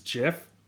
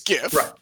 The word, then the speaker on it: GIF: But there was a point in time where there were regularly kind of like dust ups over GIF versus JIF pronunciation. – Sure. It's graphic interface format, so it's GIF. – Right. Correct. That is correct.